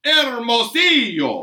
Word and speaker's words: Hermosillo: Mr. Michael Hermosillo. I, am assuming that's how you pronounce it. – Yeah, the double L makes a yes sound.